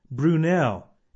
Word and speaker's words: Brunel: His first name is Isambard, and you spell that I-S-A-M-B-A-R-D, Isambard. And his last name is Brunel, and that is spelled B-R-U-N-E-L, Brunel.